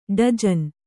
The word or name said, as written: ಡಜನ್